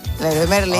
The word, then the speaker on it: Leroy Merlin: Leroy Merlin.